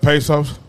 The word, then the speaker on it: Bezos: Bezos, how do you say his name? – Jeff Bezos.